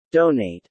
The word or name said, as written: donate